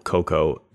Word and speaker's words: cacao: I think you can pronounce C-A-C-A-O, Coco, if you are from English.